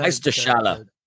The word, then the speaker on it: Meisterschale: Did I say that right? The Meisterschale. Du hast das richtig gesagt, mein Freund.